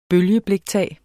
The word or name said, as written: bølgebliktag